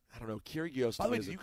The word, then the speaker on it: Kyrgios: And that's not how you pronounce his name. – Kyrgios, I know, whatever. Yeah. It should be Kyrgios, okay? So I have to say Kyrgios. There's no sound between the R and the G.